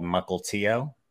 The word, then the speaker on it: Mukilteo: I grew up in a little town called Mukilteo. Most people don't pronounce it correctly, but that's how you pronounce it.